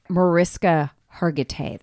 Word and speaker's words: Mariska Hargitay: Mariska Hargitay, that's how you say her name.